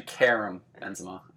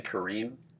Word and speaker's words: Karim Benzema: It's actually Karim Benzema. I know you're saying Kareem.